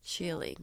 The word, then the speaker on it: Schirling: How would you pronounce Schirling? Schirling. – Yeah, like a pearl.